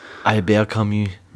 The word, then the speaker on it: Albert Camus: Das, Aber jetzt den Franzosen Albert aussprechen. Albert Camus.